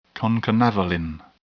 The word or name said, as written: concanavalin